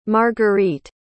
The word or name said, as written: margarite